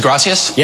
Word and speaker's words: gracias: In Latin America, this word of thanks is pronounced with a soft C. In most of Spain, the C is said as a TH. – What is gracias? – Yes.